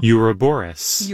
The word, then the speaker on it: Ouroboros: Ouroboros.